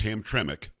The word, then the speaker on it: Hamtramck: It's Hamtramck. – Okay, and it looks like Hamtramck. I get it, but that other M in there is pronounced, and that is a majority Muslim population.